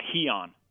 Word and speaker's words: Heon: Heon. – Heon. Okay, I wasn't sure if there was a silent letter in there or anything.